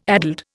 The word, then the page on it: addled: Indian